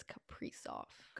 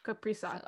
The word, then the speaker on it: Kaprizov: Some people say Kaprizov. I think I was watching highlights because I wanted to make sure and it's Kaprizov. – Kaprizov.